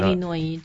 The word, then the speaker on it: Illinois: Illinois 州那个 S 也不发音 Arkansas 州那个 Arkansas, 你觉得是那样读，但不是美国人读 Arkansas.